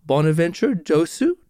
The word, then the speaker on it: Bonaventure Dossou: His name is Bonaventure Dossou. Did I get that close to right there? – Yes.